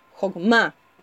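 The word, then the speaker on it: jogma (חכמה): La palabra sabiduría en hebreo es jogma o jogma, con acento en la A, jogma, Y Jouma y Biná, que es comprensión, corresponden a los dos hemisferios de nuestro cerebro.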